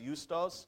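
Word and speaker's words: Justus: 23, and they put forward two, Joseph called Barsabbas, who was also called, we think it's Justice, but it's actually pronounced Eustos, and then they had a guy named Matthias.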